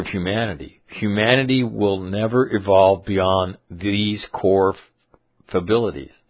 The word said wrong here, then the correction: fallibility: Am I saying that right? Fabilities? What does fability mean? Hold on. I'm looking it up. Copy, paste, enter. Fability. The tendency to make a mistake. Oh, fallibility. My bad.